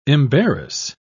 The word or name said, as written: embarrass